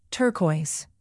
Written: turquoise

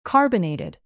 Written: carbonated

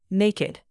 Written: naked

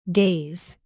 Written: gaze

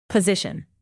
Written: position

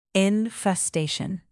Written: infestation

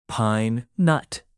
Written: pine-nut

